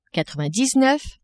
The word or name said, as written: quatre-vingt-dix-neuf